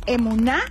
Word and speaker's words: emuná: La palabra del día de hoy es emuná, acento en la A y no lleva H.